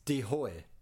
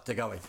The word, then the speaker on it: De Goey: If him and De Goey can get going, or his pronunciation of his name, as I found out, listen to the Collingwood podcast. Yes, I did. Is De Hoy because he's hmm. Dutch, but he likes De Goey.